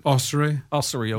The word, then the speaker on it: Ossory: How do you pronounce it? – Ossory, okay. – Yeah, that's correct.